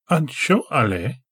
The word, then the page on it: ansho′a-le: Galindri